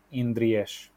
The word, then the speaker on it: Indries: And the name itself is Romanian and is pronounced Indries. – Ah, right. Mm-hmm. Yep. Yeah. So it's an esh on the end. – Yes, it's like an S-H, yeah. Like, S-H, you know, a shoe.